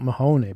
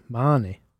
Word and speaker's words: Mahoney: And apparently, even though it's spelt Mahoney, it's pronounced Marnie.